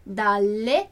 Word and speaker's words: dalle: Então, esse das, as, em italiano, sempre vai ser dalle, alle. Dalle, alle. Então, 2 L. Presta atenção na pronúncia dos 2 L's.